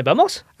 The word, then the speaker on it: vamos: Allez, vamos. – Allez, vamos. Alors, c'est un V, mais qui se dit B, alors? – En espagnol, oui. Le V se prononce B.